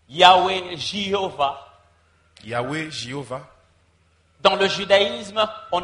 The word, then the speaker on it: Yahweh: Certains disent qu'il serait correct de prononcer Yahweh parce que dans les 22 lettres de l'alphabet hébreu, la lettre J n'existe pas.